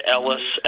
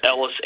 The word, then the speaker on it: Ellis: Jared Ellis, Ellis Inc. – Okay, and it's spelled E-L-I-S? – E-L-L-I-S.